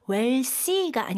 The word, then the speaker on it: wealthy: TH 발음 살짝 메롱하셔야 돼요. wealthy가 아니고 wealthy.